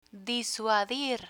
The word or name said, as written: disuadir